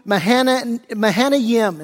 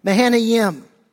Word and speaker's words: Mahanaim: 2 Samuel 17, we'll pick it up in verse 24, and we read, then David went to Mahanaim, is how you pronounce that. Mahanaim.